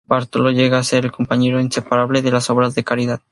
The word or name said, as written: inseparable